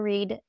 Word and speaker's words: Read: And it's read like I'm reading a book, not it read is.